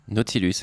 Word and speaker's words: Nautilus: Nautilus, exactement. C'est comme ça que ça se prononce, même si le U final est un W.